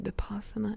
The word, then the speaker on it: Vipassana: Okay, so a while ago on the Thoughtful podcast, I was speaking about Vipassana, but I was saying it very wrong, and I don't even remember. I was like saying Vipassana, or I was definitely adding a letter, but I couldn't remember what it was. But Vipassana, how do you say it? Vipassana.